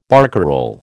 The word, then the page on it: barcarole: American English